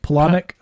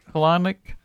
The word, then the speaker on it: Palahniuk: Oh, Palahniuk. – Palahniuk.